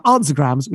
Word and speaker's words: Ansagrams: So they are Answergrams, which is spelled Ansagrams. So it's like Answergrams, but anagrams, but with the S in there.